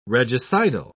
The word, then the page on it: regicidal: British English